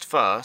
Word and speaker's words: Pho: though we all know Faux is pronounced Pho, so very clever, for their reviews on Apple Podcasts.